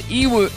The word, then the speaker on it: Yiwu: It's called Iwu. – Spell it. – Y-I-W-U. But it says pronounced E-O.